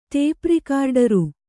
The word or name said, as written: ಟೇಪ್‍ರಿಕಾರ್ಡರು